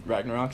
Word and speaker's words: Ragnarok: So unless I hear awesome things about Ragnarok, is that how you say it? – Yeah.